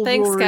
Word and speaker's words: Jewelry: Jewelry is a three-syllable word, not four.